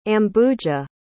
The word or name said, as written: ambuja